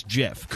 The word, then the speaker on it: GIF: I will say she is 100% correct that the guy who invented it says it's JIF. – Correct. – And I am with Violetta in that means it's JIF. Some people say, I don't care, right? – Because phonetically it should still be GIF, but that's your argument. If the guy made it up, I give him the ability to pronounce it.